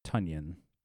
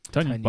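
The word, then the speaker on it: Tonyan: Also, he is vehemently telling everyone it's pronounced Tunyon. – Tunyon? – Like Paul Bunyan, but with a T. – All right. Tunyon.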